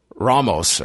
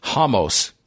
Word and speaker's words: Ramos: Because in this one where you have Davi Hamos, as it's pronounced by the broadcasters, and then you have Bruce Buffer pronouncing the R as Ramos,